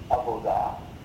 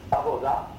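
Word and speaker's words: avoda (עבודה): Parce que le mot hébreu, avoda, le B se prononce V, avoda, signifie beaucoup de choses en hébreu.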